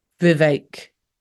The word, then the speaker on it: Vivek: he pronounced it Vivek, like ache. Vivek.